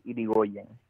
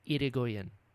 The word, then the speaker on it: Irigoyen: But the correct pronunciation is Irigoyen. – Irigoyen.